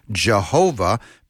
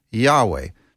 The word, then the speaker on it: Yahweh: And so in the Latin, when it was translated into Latin, some thought that it would be pronounced Jehovah, because Latin, I think, didn't have a Y. It had a J in its place. And so they came up with Jehovah instead of Yahweh. But the name is, I believe, that YHWH should be pronounced Yahweh.